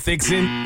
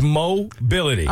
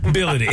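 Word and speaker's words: Moh Bility: It is not Fixin'. It is Mobility. – Oh, no. – Nah. I've never seen Mo spelled like this. My man spells his name M-O-H, last name B-I-L-I-T-Y.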